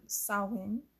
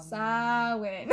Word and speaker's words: Samhain: On Samhain is how you say it. Just to let everybody know. Samhain. I could say it a couple more times.